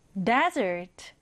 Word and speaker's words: desert: 사막은 영어로 desert 이렇게 읽어요. 강세는 앞에 강하게 주면 됩니다. 그러면 desert 이렇게 발음이 되겠죠? 자, 그러면 우리 이 단어를 오른쪽에 정확하게 써보도록 하겠습니다.